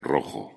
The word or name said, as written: rojo